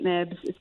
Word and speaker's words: Knibbs: Twitter is at Kate Nibbs. My last name is K-N-I-B-B-S, and Kate is spelled how it normally is.